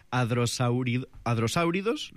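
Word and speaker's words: hadrosáuridos: Ahora diré otro de estos nombres que para el común de los mortales nos suena raro, que son los adrosáuridos, ¿lo digo bien? ¿Adrosáuridos? – Perfecto, lo has dicho perfecto.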